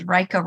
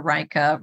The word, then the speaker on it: Rajka: And it's, I always tell people my name is Rajka, Rajka, Rajka, rhymes with Micah, Micah, Micah.